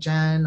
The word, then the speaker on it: Zhen: You pronounced it because it's like Jen or Jenny. Yeah, you did. And you said Jen.